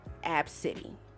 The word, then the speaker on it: Abcde: Now, the baby's name is spelled A-B-C-D-E, and it's pronounced Abcity.